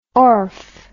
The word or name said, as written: orfe